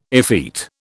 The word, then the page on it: effete: British English